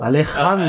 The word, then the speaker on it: Alejandro: Er heißt äh, Alexandro. – Alejandro. Äh, Alejandro. Alejandro Chodorowski.